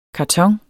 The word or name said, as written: karton